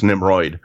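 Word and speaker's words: Nimród: Predators came out in 2010, directed by, I don't know how to pronounce this, I'm going to say Nimrod Antal. I mean, Nimrod is the way it's spelled, Nimrod. But there's an accent over something. – It's actually even worse. It's Nimroid.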